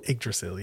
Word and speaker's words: Yggdrasil: Yggdrasil, yeah.